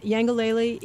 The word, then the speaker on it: Yangalele: which is called The Land of Yangalele. Am I yes. – saying that right? – Yes. – And Yangalele in Kikongo means happiness.